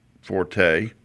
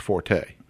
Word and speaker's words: forte: Do you know when you use it in that term, it's not pronounced forte? – What is it? – It's pronounced fort. – Fort.